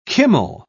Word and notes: kummel: British English

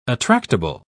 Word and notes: attractable: British English

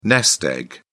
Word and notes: nest egg: British English

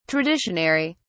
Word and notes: traditionary: British English

